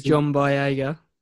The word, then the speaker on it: John Boyega: Mr. John Boyega.